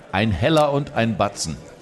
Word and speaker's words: Ein Heller und ein Batzen: Ein Heller und ein Batzen.